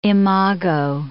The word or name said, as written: imago